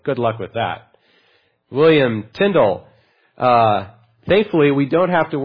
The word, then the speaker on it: Tyndale: Turns out you pronounce it Tyndale, like spindle, not Tyndale.